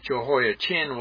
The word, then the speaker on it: Jehoiachin: Verse 2 solidifies the dating of the prophecy using the captivity of Judah's king, Jehoiachin, C-H-I-N. I pronounce it that way, so it's more easily to be recognized as opposed to his father, Jehoiakim. So Jehoiachin was Jehoiakim's son.